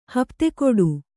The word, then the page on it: ಹಪ್ತೆಕೊಡು: Kannada